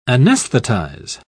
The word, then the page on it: anaesthetize: British English